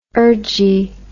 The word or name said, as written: urgy